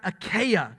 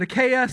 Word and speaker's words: Achaia: Some people pronounce it Achaia, some people pronounce it Achaia, some people prefer to, to dabble with the far more refined sounding Achaia. Good luck, all right? Which uh, all those options work, all right?